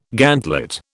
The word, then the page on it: gantlet: British English